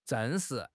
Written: 证实

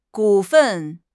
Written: 股份

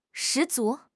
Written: 十足

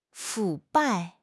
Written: 腐败

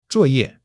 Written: zuo4 ye4

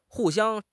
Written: hu4 xiang1

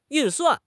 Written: 运算

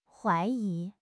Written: huai2 yi2

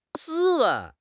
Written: 斯文

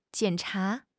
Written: jian3 cha2

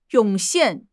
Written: 涌现

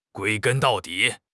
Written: 归根到底

